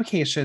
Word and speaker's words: Acacia: he was like, Acai? And I was like, no, Acacia.